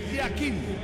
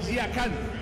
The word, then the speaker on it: Yakin: Uno es pronunciación suiza y la otra pronunciación turca. En el suizo se pronuncia así: Murat Yakin. En turco, Yakan. Cambia la última parte de su apellido.